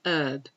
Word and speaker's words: herb: But to be honest, I think this might be an American list, because in American English, they usually say herb. But we do pronounce the H, so I'm going to ignore that.